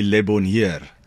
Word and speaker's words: Le Bonheur: Yeah, I think the South African culture, you know, speaking Afrikaans, we kind of pronounce it the way we read it. – Okay. – And that would be Le Bonheur.